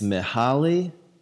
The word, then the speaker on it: Mihaly: It's Mihaly, Mihaly Csikszentmihalyi. Mihaly Csikszentmihalyi. It's M-I-H-A-L-Y, that's Mihaly, I'm pretty sure. And then C-S-I-K-S-Z-E-N-T-M-I-H-A-L-Y-I. So it's like Csikszentmihalyi. The first name is Mihaly.